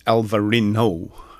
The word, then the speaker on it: Alvarinho: Yeah, this uh, the uh, estate is Aveleda, um, and it's from the Vinho Verde region, DOC there, um, and it's Alvarinho is how they pronounce it.